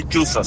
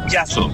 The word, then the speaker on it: chazo: No se dice chuzo, se dice chazo.